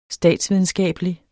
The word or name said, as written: statsvidenskabelig